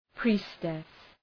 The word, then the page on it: priestess: ‘pri:stıs